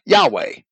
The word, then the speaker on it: Yahweh: Recent scholars, accordingly, with but few exceptions, are agreed that the ancient pronunciation of the name was Yahweh, the way we spell it in Christian Identity. The first H sounded at the end of the syllable.